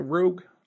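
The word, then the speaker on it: Roeg: Am I pronouncing the Rogue? I would think that's Rogue. Rogue is how I pronounce it, but that's from a Scotsman, so it might as well be something else.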